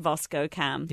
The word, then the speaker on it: VSCO: Didn't know it's pronounced VoscoCam.